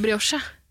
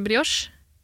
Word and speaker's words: brioche: Er det en stum i det? – Brioche. – Det er jo en i ordet. – Ja, men det er fransk. – Ja. – Ja, i-en skal ikke uttales. Nei, det er kanskje s-en da, på slutten. – Bro.